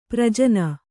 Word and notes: ಪ್ರಜನ: Kannada

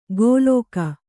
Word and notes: ಗೋಲೋಕ: gōlōka

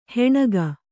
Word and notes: ಹೆಣಗ: Kannada

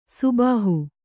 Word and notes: ಸುಬಾಹು: Kannada

